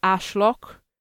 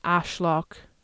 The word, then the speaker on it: Arschloch: and Ashlok, which is spelled A R S C H L O C H, which means asshole. So pretty simple. Shiza and Ashlock, shit and asshole.